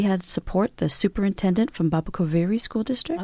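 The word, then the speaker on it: Baboquivari: You're going to have to correct my pronunciation, but Babo Kivari? – Babo Kivari. – Babo Kivari.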